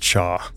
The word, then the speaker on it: Chia: Their names are spelled C-H-I-A, but it's pronounced Cha.